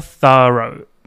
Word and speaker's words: thorough: で、後ろは row です。 row。ね、 R-O-U を O って発音します。 GH はもちろん発音しません。 row。だから thorough ですね。発音 thorough。